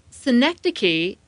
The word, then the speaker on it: synecdoche: Synecdoche is a specific type of metaphor in which you use part of something to describe all of it.